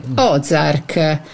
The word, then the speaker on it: Ozark: che ci parla di Ozark. Ho detto bene? – Esattamente, Ozark.